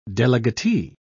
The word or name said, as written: delegatee